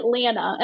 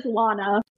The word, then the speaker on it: Lana: So I say Lana, and I like wrote her as Lana, but my husband, I swear every character I write, he like pronounces the name the opposite. So he says Lana.